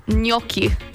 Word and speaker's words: gnocchi: Gnocchi. – Oh, you nailed it. Yeah, somebody said gnocchi or gnocchi.